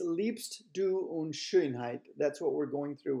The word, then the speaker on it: Schönheit: Schönheit is pronounced as if it was Shane Height. And you make an ooh lip when you say Shane. So it's Schönheit.